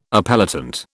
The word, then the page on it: appellant: British English